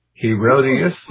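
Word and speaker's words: Herodotus: Herodias, if I'm pronouncing it right. – The what? – Herodias, H-E-R-O-D-O-R-U-S.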